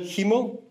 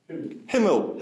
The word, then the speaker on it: Himmel: Norse hefen, Danish hemen, Dutch hemel, High German hemel. Is that maybe correct? Himmel, himmel, thank you.